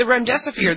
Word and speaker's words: remdesivir: All right, so then the one other thing, the National Institute of Health, says drug trials are showing that this remdesivir, is that how you pronounce it? – Remdesivir. – Mm-hmm.